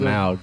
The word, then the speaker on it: Smaug: Is it pronounced smog or smaug? – What? – Like the great lizard. It's smaug. Yeah, I think so.